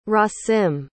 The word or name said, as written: rasim